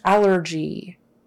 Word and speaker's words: allergy: Okay, and then allergy. Allergy. Stress is on the first one, the first syllable, allergy, allergy.